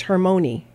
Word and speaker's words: Hermione: The end vowel is sounded. So you're right. It's Hermione.